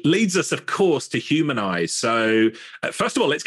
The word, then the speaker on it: Humnize: So for people listening while they're out walking the dog or driving in the car, the company name is spelled H-U-M-N-I-Z-E. So there's no A in the middle of there.